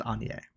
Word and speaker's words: Agnes: A-G-N-E-S. – Origin. – But it's pronounced Anya.